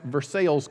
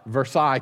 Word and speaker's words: Versailles: It's pronounced Versailles, Kentucky, for those of you not from Kentucky, not Versailles, Kentucky. I did not get it wrong. That's just how it is in Kentucky.